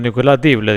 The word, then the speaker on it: Dible: Nicolás Dible, Dible. – Nicolás Dible. – Es que no lleva acento, Nicolás Dible, digo, que se pronuncia.